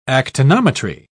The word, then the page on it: actinometry: British English